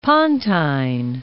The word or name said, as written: Pontine Islands